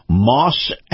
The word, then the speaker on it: Maus: is brought to you by Moss Acura. Moss is spelled M-A-U-S, Acura, the dealership right here in Tampa.